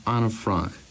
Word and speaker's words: Anne Frank: It's a pleasure to hear you say Anne Frank in the right pronunciation.